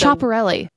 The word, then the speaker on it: Schiaparelli: Taylor's in a custom Scaparelli dress. Okay. – It's Schiaparelli.